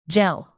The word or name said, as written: gel